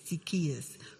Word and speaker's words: Zacchaeus: Zacchaeus. – Who supervised all the tax collectors. He was very eager to see Jesus and kept trying to get a look at him through the massive crowd. Since Zacchaeus Zacchaeus was a short man and couldn't see over the heads of the people.